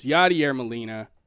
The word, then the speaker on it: Yadier Molina: Yadier Molina.